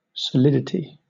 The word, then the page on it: solidity: British English